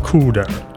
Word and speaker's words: cdr: Note, CDR is pronounced CUDA.